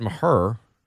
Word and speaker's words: Maher: Then you go out, you sign Brett Maher. That's how you pronounce it, according to the media guide.